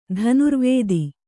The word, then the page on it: ಧನುರ್ವೇದಿ: dhanuvēdi